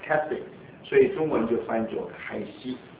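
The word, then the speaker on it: Keswick: 那么有一个地方叫 Castwick，那个 Wick I 没有发音，不是 Wick， Castwick。所以中文就翻作开西。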